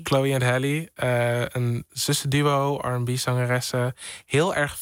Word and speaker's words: Halle: Chloe X, is het heel of Halle? – Oh, het is Hallie. Geen van beide. Het is Chloe en Halle. – Oh, Chloe en Halle. – Halle, het zijn zusjes.